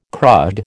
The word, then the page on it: crud: American English